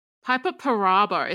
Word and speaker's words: Piper Perabo: Piper Perabo. Is that how you say it? – Oh yes, yes.